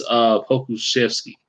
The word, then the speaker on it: Pokuševski: It's Pokuševski. I heard it so much during the game. That's how I was able to get the right pronunciation.